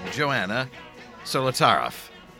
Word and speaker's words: Joanna Solotaroff: Let's go now to my conversation with Joanna Solotaroff. Joanna Solotaroff.